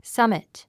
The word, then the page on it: summit: sʌ́mit; サミト